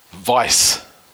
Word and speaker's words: Weis: Can't, don't know if it's a long time listener though, but certainly this year, just his first name, Vice, as in, if I'm, you know, pronouncing it with the, you know, correctly. – Was it a V? – No, it's not. It's, you know, W-E-I-S, or a Vice.